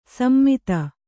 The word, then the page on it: ಸಮ್ಮಿತ: sammita